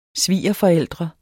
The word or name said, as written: svigerforældre